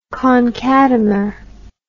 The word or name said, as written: concatemer